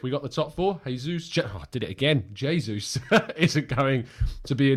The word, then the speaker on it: Jesus: Jesus is pronounced the Portuguese way, not Jesus. Jesus in the Spanish way.